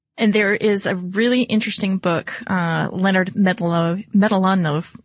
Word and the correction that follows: Mlodinow: Excuse me. Let me try that again because I'm sure that at least his family would appreciate that I pronounced it correctly. But Leonard Mlodinow.